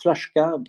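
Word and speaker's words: Flaška: Alors, le concepteur de la carafe Flaska et des bouteilles Flaska et de tous les produits Flaska, ça se prononce Flaska parce qu'il y a un tréma inversé sur le S en slovène.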